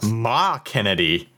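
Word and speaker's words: McKennedeigh: And apparently it's pronounced, according to the way she wrote it, Ma Kennedy.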